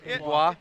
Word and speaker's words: Dubois: Pierre-Luc Dubois, I think is how you pronounce it. – Dubois. Dubois, yeah.